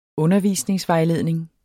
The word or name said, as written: undervisningsvejledning